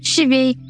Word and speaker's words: щавель: В разных регионах России ходят другие названия этого растения – кислица, щавель, кислуда, кислушка и другие. Ударение в слове «щавель» тоже ставят где на первый, где на второй слог. Хотя правильным в целом считается ударение именно на «е».